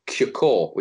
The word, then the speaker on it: Cukor: The correct answer she was looking for is a director who was called George Dewey, and I think the surname is pronounced Cukor, which is spelled C-U-K-O-R, which obviously would fit the crossword puzzle.